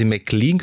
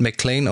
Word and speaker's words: McLean: Und zwar Lucy McLean, obwohl sie McLean gesprochen wird in der Serie, wurde sie immer super merkwürdig, ja. Ne? – Also Lucy McLean, aber wird McLean ausgesprochen.